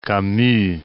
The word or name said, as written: Camus